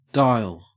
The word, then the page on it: dial: Australian English